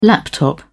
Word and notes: laptop: British English

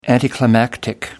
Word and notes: anticlimactic: British English

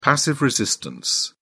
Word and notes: passive resistance: British English

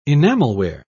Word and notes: enamelware: British English

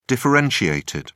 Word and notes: differentiated: British English

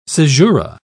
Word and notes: cesura: British English

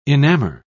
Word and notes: enamor: British English

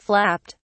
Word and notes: flapped: British English

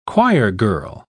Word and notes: choirgirl: British English

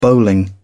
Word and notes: bowling: British English

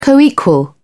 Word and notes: coequal: British English

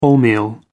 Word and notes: wholemeal: American English

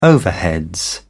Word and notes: overheads: British English

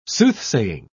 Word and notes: soothsaying: British English

